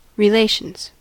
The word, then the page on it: relations: American English